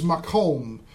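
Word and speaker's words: maqom: The Hebrew word for this is makom. It is M long A dash Q O M.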